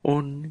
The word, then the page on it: un: Lwaitel